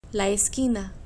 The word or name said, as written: la esquina